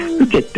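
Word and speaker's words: 深井: このおふけ焼きとはですね、名古屋城内で焼かれてたですね、いわゆる殿様のお庭焼きってものでですね、で、ふけってのは、深いって字に、井戸の井って書いて、これふけって読むんですけど、これはですね、もともとの今、沼地とか湿地帯のことを指す言葉なんですね。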